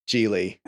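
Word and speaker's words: Gigli: So, Geely. – His name rhymes with really.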